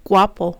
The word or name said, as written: guapo